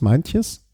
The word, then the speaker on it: Meintjes: Meintjes.